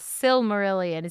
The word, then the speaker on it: Silmarillion: So is he in the, I'm going to say it wrong, Silmarillion? – Silmarillion.